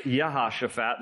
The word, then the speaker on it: Jehoshaphat: His name in Hebrew is pronounced Yehoshaphat. There's no J in the Hebrew alphabet.